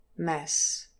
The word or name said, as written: mess